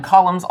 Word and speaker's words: columns: So this first thing looks like a couple of tops of columns, and columns also has a silent letter in it. The letter N is not something we would typically say or pronounce in that word.